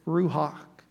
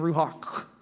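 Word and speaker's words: ruach (רוח): That word in Hebrew is ruach. And you only say it right if you get a little phlegm at the end, ruach, right?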